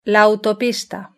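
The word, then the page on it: la autopista: Spanish (Spain)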